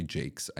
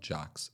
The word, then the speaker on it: Jacques: Sophie Jacques seems to be Sophie Jakes, I guess they say, but it's spelled Jocks.